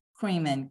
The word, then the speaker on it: Kremin: Lainey, L-A-N-N-I-E, Creeman, K-R-E-M-I-N.